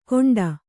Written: ಗೊಂಡ

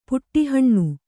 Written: ಪುಟ್ಟೇಹಣ್ಣು

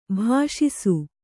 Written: ಭಾಷಿಸು

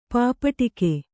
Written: ಪಾಪಟಿಕೆ

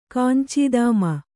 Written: ಕಾಂಚೀದಾಮ

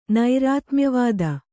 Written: ನೈರಾತ್ಮ್ಯವಾದ

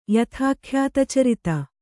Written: ಯಥಾಖ್ಯಾತಚರಿತ

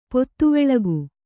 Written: ಪೊತ್ತುವೆಳಗು

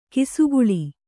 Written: ಕಿಸುಗುಳಿ

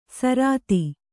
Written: ಸಾರತಿ

